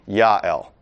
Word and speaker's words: Joel: Joel, or as you will sometimes hear purists pronounce it, Joel, which if you hear a purist pronounce it Joel, you can correct them because they're wrong. First of all, we generally pronounce things in English since we're English speakers, so Joel is perfectly acceptable. Secondary, if you really want to pronounce it in Hebrew, it would be Yael, not Joel.